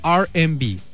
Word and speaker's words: R&B: Mujer estadounidense que tuvo impacto en el mundo del jazz, el soul y el R&B, que se pronuncia R&B, casi casi como los Airbnb, pero no, son diferentes.